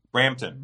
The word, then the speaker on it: Brampton: Brampton.